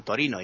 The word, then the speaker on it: Torino: Y ahora nos vamos a Turín, que los italianos llaman Torino y en italiano se dice Torino, ¿no?